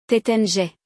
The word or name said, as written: Taittinger